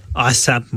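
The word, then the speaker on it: A$AP: Assap Mob, OK. – Dans le fond, le signe de dollar, là, tu le prononces comme un S.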